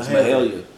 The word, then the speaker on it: Mahalia: Malia, I'm saying that right. Mahalia. Mahalia was an honorable mention.